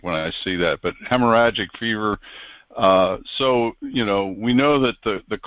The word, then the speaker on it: hemorrhagic: And they're going to come up with something new, which could be this hemorrhagic, or how do you pronounce that? – The what? – The fever. Hemorrhagic.